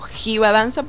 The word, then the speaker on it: Jiva Danza: Se escribe con J, sí, como Jiva Danza, pero se pronuncia Jiva Danza.